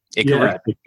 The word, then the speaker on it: Akira: I'd never even heard of Akira. Am I saying that right? – Akira.